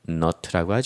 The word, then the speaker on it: knot: 그럼 이렇게 코 바늘에 실을 넣는 거, 그래서 매듭을 만드는 거를 K-N-O-T, 거기 어원프레드 나왔죠. knot라고 하죠. 이것도 K는 이제는 없어지고 knot, 그럼 매듭이라는 뜻이 됩니다.